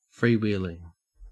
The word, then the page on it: freewheeling: Australian English